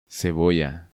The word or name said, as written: Cebolla